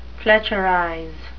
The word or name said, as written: fletcherize